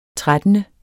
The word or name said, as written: trettende